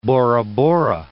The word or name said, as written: Bora-Bora